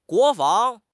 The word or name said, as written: 国防